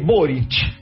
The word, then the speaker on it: Boric: es Boric. – Eh, en Chile se pronuncia mucho la CH con ese tipo de apellidos, así que es Boric.